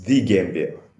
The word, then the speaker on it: the Gambia: It's the Gambia. The article "the" is always pronounced before the nation, the Gambia.